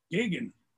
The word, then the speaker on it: Geoghegan: Geoghegan.